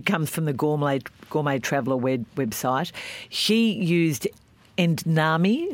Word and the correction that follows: edamame: I call them edamame.